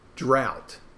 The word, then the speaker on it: draught: Drought. Now, when a drought, when you see on a Guinness bottle, this is very specific. D-R-A-U-G-H-T. Drought. Right? Drought. Or, in England, they call it draft. The G-H-T is T, so it's draft.